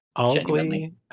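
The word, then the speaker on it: Algli: Augli. It's A-L-G-L-I. I thought it was Agli until I saw the first L there.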